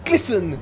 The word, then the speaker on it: glisten: Listen when I say glisten, because it rhymes with listen.